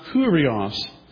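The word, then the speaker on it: Kyrios: In Greek, it's pronounced Kurios.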